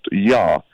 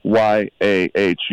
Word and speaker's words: Yah: And let me tell you, in the original Hebrew characters, the breath of life is a character which also means it's pronounced Yah, Y A H, Yah.